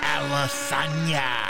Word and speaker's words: Adesanya: Shout out to Izzy Adesanya. Is that how you say it? – Izzy Adesanya.